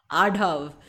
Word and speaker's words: Adhav: Adhav.